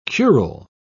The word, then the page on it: curule: British English